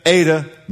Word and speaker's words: eta: The second letter looks like an N, is an eta. Everyone say eta. And you pronounce it like A-Y, A. So that's bay.